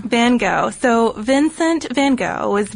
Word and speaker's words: Van Gogh: And just a note, too, before we go any further, you can tell by now we're going to say Van Gogh, which is the standard American pronunciation.